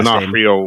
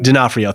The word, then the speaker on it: D'Onofrio: Yeah, Vincent, I can never pronounce his last D'Onofrio. – Name. D'Onofrio, thank you.